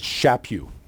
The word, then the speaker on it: Chaput: I don't know if I said that. My staff is back there, and so they'll correct me. Now they said I mispronounced it again. So anyway, it's Chaput. There we go. Got it right.